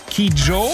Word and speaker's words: Kidjo: Klingt einfach, je nachdem, wie man nuschelt, so ähnlich. Angelique Kid Joe.